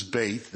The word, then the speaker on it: Beth: And it's pronounced B-E-T-H in English. It's bait.